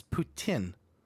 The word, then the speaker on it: poutine: All my life, I grew up calling it poutine. – Yeah, because he's English. – Apparently, the proper pronunciation is poutine. – Poutine. – Yeah. – It's the French word.